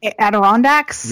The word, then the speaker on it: Adirondacks: Adirondacks? – Adirondacks? – Yeah.